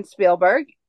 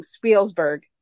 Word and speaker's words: Spielberg: Tiffany said Steven Spielberg, and then there was a funny confessional because Corey could not say his name. He kept saying Spielsberg.